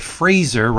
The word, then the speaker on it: Fraser: By Fraser, rhymes with laser, Irving.